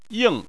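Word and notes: ying: tone 4

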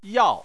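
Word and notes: yao: tone 4

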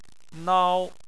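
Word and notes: nao: tone 1

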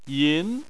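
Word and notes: yin: tone 2

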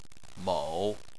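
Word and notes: mou3: tone 3

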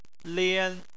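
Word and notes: lian: tone 1

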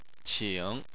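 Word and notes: qing: tone 3

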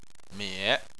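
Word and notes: mie3: tone 3

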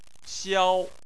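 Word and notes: xiao: xiao1; tone 1

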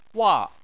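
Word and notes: wa: wa4; tone 4

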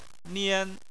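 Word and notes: nian: tone 1

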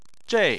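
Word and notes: zhei: tone 4

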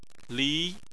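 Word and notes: li: tone 2

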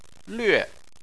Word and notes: lve: 4

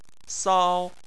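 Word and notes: sao: tone 1

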